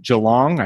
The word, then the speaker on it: Geelong: Neil is a small business owner and podcaster down under, down in Australia, just outside of Melbourne in Geelong.